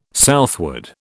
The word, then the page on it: southward: British English